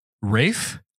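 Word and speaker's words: Ralph: My third pick is going to be Rafe.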